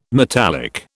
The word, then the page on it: metallic: British English